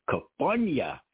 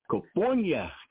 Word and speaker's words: Kufunya: I like how she pronounced it to me very vibrantly. Kufunya. – Okay. – She said Kufunya.